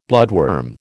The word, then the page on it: bloodworm: American English